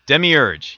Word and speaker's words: Demiurge: It's called, and it has this pronounced, I hope I didn't screw this up. It's Demiurge? – Demiurge, yes.